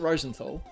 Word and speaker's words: Rosenthal: Well, if you're German. Years. – Oh, okay. – But I pronounce it Rosenthal.